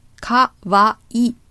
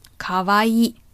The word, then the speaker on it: kawaii: And kawaii. Kawaii. Cute. Kawaii. So it's a long E. Kawaii. Don't forget the E at the end. It's an E adjective after all.